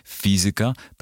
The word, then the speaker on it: fizyka: Jak pisze PWN, w starannej wymowie wymienione wyrazy należy akcentować na trzeciej sylabie od końca. Zatem matematyka, fizyka, pedagogika, muzyka.